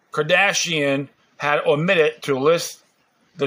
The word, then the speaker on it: Kardashian: In 2015, Kim Kardashian, okay, that's pronounced her name, Kardashian,